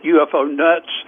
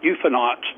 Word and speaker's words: UFOnauts: Well, I have to be careful with that because people have pronounced it UFO nuts. So we in the trade, we call it UFOnauts.